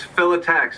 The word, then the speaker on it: Phyllotaxis: Philotaxis stuff, and I hope I'm saying that right, because the first time I... Philotaxis, right? – It's Philotaxis. – Philotaxis, okay.